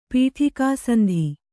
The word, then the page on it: ಪೀಠಿಕಾಸಂಧಿ: Kannada